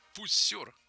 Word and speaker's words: Пуссер: В 29-м Анри Пуссер. Нет, Пуссер. Такое у них ударение.